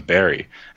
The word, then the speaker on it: Barre: The name of the town is Barry. Anyone that's ever been here before knows it's called Barry. It's not Barre."